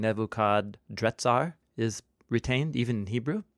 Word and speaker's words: Nebuchadrezzar: Yeah, the Babylonian name is Nebuchadrezzar, with an R instead of an N. Not Netzar, but Retzar. Nebuchadrezzar.